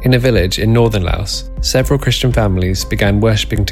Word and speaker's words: Laos: Susan said, you pronounce this country, Laos, without the S. So it's got a silent S. I either Lao or Lo.